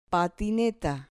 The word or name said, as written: patineta